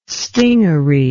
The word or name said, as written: stingaree